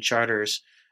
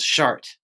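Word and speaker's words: Chartres: Yeah, if there's a gravitational center, it's in the French Quarter at the intersection of St. Philip and Charters. Charters being the local pronunciation for those of you touring France right now. You would pronounce Chart.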